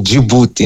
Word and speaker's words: Djibouti: Agora, indo diretamente à questão, dizer que na nossa pronúncia aqui em Moçambique, o nome Djibouti é mesmo Djibouti, então vão ficar à vontade eu dizer Djibouti diretamente, como nós dizemos aqui em Moçambique.